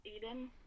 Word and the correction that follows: Alden: or how do you pronounce it? – Alden. – Alden. Okay, thank you. I was like, I can't tell if my notes is an L or an I.